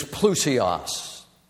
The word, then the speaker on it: plousios: The Greek word is plousios. If you're taking notes, it's spelled P-L-O-U-S-I-O-S. P-L-O-U-S-I-O-S.